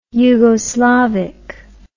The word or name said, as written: yugoslavic